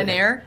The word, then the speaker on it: heir: So I'd say the king and queen maybe had, like, tops 1 year to work on making a hair, or how do you say it? – An heir. – An heir, thank you.